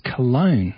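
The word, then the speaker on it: Colón: And veteran Bartolo Colon, which is an unfortunate name, who's currently pitching for the New York Mets. They conveniently pronounce his name as Cologne.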